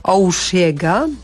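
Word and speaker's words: Osheaga: w Montrealu odbywał się festiwal Oushiega, mniej więcej tak nazwę należy wymawiać.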